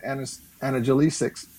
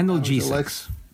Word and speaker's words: analgesics: Unfortunately, I do not get analgesics. How do you say that? Analgesics.